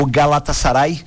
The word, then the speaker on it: Galatasaray: Eu falava que foi prato cheio porque agora à tarde teve o, deixa eu ver se eu consigo pronunciar corretamente o nome do time, o Galatasaray, e bem isso. – Não, ficou bonito.